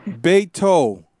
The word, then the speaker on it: Beto: Beta O'Rourke. I mean, Beto. – Beto. It's Beto. But I pronounce it. I was pronouncing it Beto for a long time. It's Beto. – It's Beto. – It's Beto, yeah.